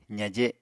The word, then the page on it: zaje: apatani